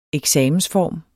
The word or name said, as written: eksamensform